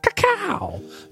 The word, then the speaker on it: cacao: Cacao is a mystical bean. – I would have been upset if you didn't say it like that, by the way. – Cacao.